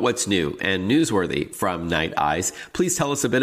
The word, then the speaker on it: Nite Ize: And so I wanted to invite onto the show Rick Case, the founder and CEO of Night Eyes, which is spelled N-I-T-E-I-Z-E.